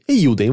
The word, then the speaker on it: Eiyuden: Se escreve E-I-Y-U-D-E-N. Eiyuden, vou falar Eiyuden.